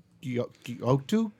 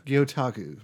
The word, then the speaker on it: Gyotaku: Gyotaku. – Gyotaku.